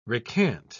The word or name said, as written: recant